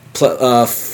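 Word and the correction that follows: Plascencia: I can't even pronounce. I'm sorry. – FCO Placentia. – Placenta. – I didn't want to say it. Placentia.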